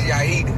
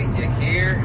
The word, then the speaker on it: Yair: It's called, my name is Yair, but white people always say Yair. Like, they always say something different.